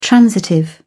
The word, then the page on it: transitive: British English